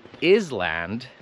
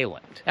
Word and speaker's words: island: Albo też kiedyś miałam taką młodą uczennicę, która mi powiedziała, że w szkole jej nauczycielka mówi island zamiast island. Ja myślę, że większość ludzi wiedzą, że to jest island i nie island.